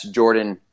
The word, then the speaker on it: Jordon: Just type my name, and that's Jordan Harrison. And my first name is spelt a little different, so it's J-O-R-D-O-N, last name Harrison.